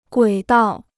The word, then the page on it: 轨道: guǐ dào